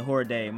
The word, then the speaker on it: Jorge: And the main event of the evening, fellow Englishman Darren Till versus Horde I said Jorge. My fault, bro. Jorge Masvidal. Masvidal. I don't know why I always say his last name wrong.